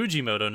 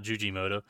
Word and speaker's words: Fujimoto: Be Fujimoto, not Jujimoto.